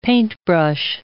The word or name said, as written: paintbrush